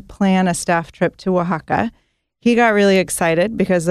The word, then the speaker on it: Oaxaca: Oaxaca is spelled phonetically. It's W-A-H-A-K-A.